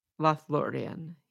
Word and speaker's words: Lothlórien: Okay, so let me get that pronunciation one more time. Lothlórien, is that how you say it? – Lothlórien, yes.